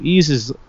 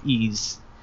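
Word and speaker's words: Ys: But the whole problem with that game on Master System was they put an apostrophe between the Y and the S, which is not how it's said. So, Ease is like a mythical land, basically, in French, I think. and that's how it's pronounced.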